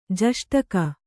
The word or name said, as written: ಜಷ್ಟಕ